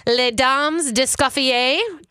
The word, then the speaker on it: Les Dames d'Escoffier: she's part of a very cool group called Les Dames d'Escoffier. Did I say that right? – Yeah, you did.